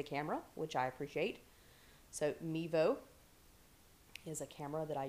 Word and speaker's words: Mevo: It's called a Mevio, I believe is the way that you pronounce it. It is Mevo, actually. M-E-V-O.